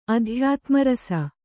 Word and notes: ಅಧ್ಯಾತ್ಮರಸ: Kannada

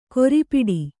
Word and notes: ಕೊರಿಪಿಡಿ: Kannada

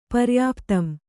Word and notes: ಪರ್ಯಾಪ್ತಂ: paryāptam

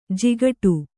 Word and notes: ಜಿಗಟ: Kannada

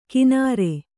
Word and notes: ಕಿನಾರೆ: kināre

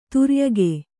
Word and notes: ಧೌರೇಯಕ: dhaurēyaka

